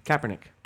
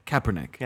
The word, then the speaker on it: Kaepernick: How do you say his last name? – Kaepernick. – Kaepernick.